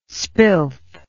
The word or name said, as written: spilth